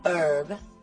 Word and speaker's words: herb: La letra H no se pronuncia en palabras tales como hour y herb.